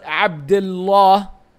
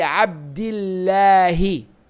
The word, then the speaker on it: عبد الله: in this case for lafzul jalala the pronunciation is changed so you don't say عن أبي عبد, عبد الله because also that sounds very heavy on the ear. You say عن أبي عبد الله.